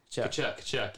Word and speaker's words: Tkachuk: What, Tkachuk or whatever? – Yeah. – The guy that was going at it with Drysdale all year. – Kachuk, yeah. I could say his name how it looks. Kachuk.